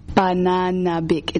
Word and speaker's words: Pananabik: But the one that I would say you guys should listen to is Pananabik. – How do you pronounce that? – Pananabik. It's literally how it's spelled.